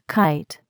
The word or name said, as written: kite